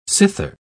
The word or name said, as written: cither